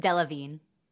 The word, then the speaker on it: Delevingne: And Cara Delevingne, oh goodness, how do you pronounce her name, HG? I know you can. – I think it's just Delevingne.